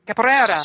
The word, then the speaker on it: capoeira: So, capoeira? Is that how you say it? Capoeira? – Pretty close, yeah. Tend to kind of, like, mangle it all into one. – Capoeira.